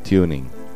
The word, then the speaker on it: TuneIn: Puedes descargarte en los distintos dispositivos el aplicativo Tuning, se escribe T-U-N-E-I-N, Tuning.